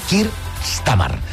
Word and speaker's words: Keir Starmer: Keir Starmer, que em sembla que no sàpiga pronunciar jo aquestes hores del matí. Keir Starmer.